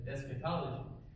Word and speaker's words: eschatology: But in English, it's pronounced differently. In English, está pronunciado diferente escatology.